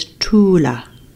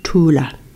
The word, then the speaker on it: Thula: And the spelling for Tula is T-H-U-L-A, but pronounce it as Tula, Tula, Tula Tul.